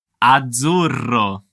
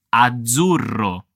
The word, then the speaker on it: azzurro: Stiamo attenti alla pronuncia della doppia Z in questa parola. Azzurro. Come puoi sentire, sto pronunciando la Z come una Z, Z, Z. Quindi è un suono forte. Prova a ripetere con me.